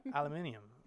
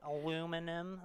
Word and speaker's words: aluminium: Do you say aluminium? – Yeah, because that's how you say it. It's like aluminium. Like aluminum.